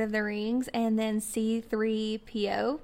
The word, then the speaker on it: C-3PO: You're saying all the same letters and numbers in this great sequential order. Yes, it is C-3PO. You did say that correctly.